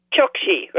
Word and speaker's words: Chokshi: And so I just want to introduce everybody to Roshani, I'm saying it right, Chokshi, right? – That's correct.